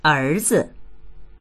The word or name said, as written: er2zi